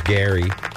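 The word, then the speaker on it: Gerry: Their Vizio, as the Golden Bachelor, Gary, spelled like Jerry, by the way, with a G, but he's Gary, is going to hook up live on international television through satellite to...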